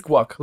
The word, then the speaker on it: guaiac: It's guac, like guacamole. – Well, it's G-U-A-I-A-C, so I'm assuming it's like guaiac.